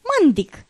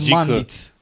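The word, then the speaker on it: Mandić: Mandic, da? Așa, mă rog. – Și cred că se pronunță Mandic. – Nu știu exact, că da, este un C specific, eu nu știu care este pronunția.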